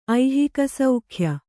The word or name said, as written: ಐಹಿಕಸೌಖ್ಯ